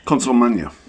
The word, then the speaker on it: Consolmagno: Consul Magna.